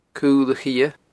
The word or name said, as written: Cul a Chidhe